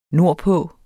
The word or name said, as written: nordpå